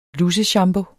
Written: luseshampoo

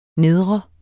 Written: nedre

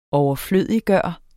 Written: overflødiggøre

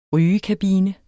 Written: rygekabine

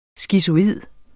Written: skizoid

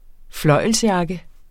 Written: fløjlsjakke

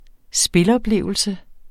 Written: spiloplevelse

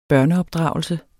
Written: børneopdragelse

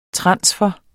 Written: transfer

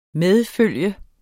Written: MEDFULGT

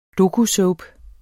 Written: dokusoap

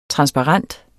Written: transparent

